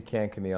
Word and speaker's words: Kankakee: I see Kankakee on the paperwork, but I didn't know how to pronounce it.